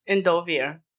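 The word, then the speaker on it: Endovier: Is it Endoviers? – Endovia.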